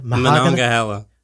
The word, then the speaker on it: Monongahela: Monongahela.